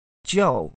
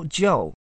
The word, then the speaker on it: Zhao: Joel. Neil Joel. Z-H-A-O.